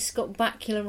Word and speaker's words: Bakula: And David as Scott Bakula rhymes with Dracula, as he appeared in Quantum Leap.